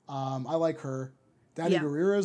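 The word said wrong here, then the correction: Danai Gurira: is rumored. Danae Gurira, I think is how you say it.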